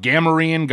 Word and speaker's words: Gamorrean: Or how do you say it? Gamorrean? – I think both are valid. Tomato, tomato. I mean, I don't. There isn't a canonical pronunciation within the Disney Star Wars movies, so I think you say it however you like.